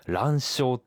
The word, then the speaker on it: 卵鞘: このカマキリの卵とか、卵嚢って呼ばれているものはですね、正式にはですね、漢字で卵の鞘と書いて卵鞘って呼んでるものなんですね。